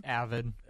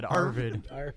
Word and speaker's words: avid: Avid. Avid. You said Arvid.